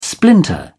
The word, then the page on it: splinter: British English